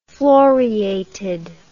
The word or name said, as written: floreated